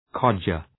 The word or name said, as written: codger